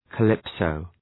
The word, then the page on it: calypso: kə’lıpsəʋ